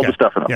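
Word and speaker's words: DiStefano: The chancellor at Colorado, last name, is that DeStefano? Is that how you say it? – Yes, Phil DeStefano.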